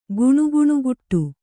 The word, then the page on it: ಗುಣುಗುಣುಗುಟ್ಟು: Kannada